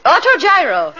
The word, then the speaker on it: autogyro: Autogyro.